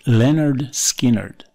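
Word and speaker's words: Lynyrd Skynyrd: Pronounced Leonard Skinnerd.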